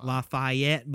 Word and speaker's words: Lafayette: It's actually pronounced Lafayette, but I ain't French.